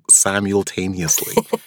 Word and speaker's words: simultaneously: Yes, I realized I had trouble pronouncing simultaneously. There's that L in there that I just kind of skip over, which I accredit to being a New Yorker.